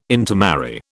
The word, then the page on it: intermarry: British English